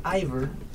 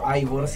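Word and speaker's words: Eivør: Bueno, esta cantante se llama Ivor, o Ivor si lo quieren pronunciar ya más, de una manera más relajada.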